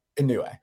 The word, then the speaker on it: Inoue: Inoue.